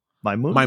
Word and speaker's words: Maimouna: How would you pronounce that? Maimouna? – Maimouna, yes.